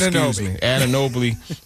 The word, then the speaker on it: Anunoby: I can never pronounce his name. Anunoby. I was going to put that L in there for some reason.